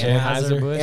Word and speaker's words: Anheuser-Busch: But you know, how do you say it? – Anheuser-Busch. – Anheuser-Busch. – Anheuser-Busch is correct.